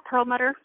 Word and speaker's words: Perlmutter: And she works as a nurse at NYU Langone Perlmutter Cancer Center. Did I say that right? – Yeah, Perlmutter Cancer Center.